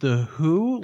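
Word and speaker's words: The Hu: And then also, I went down a weird rabbit hole and got into this band called, I think it's pronounced The Who, like kind of like The Who band, but it's The H-U.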